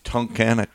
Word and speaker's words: Tunkhannock: How would you say that? Tunkhannock? Tunkhannock, Pennsylvania. Yeah.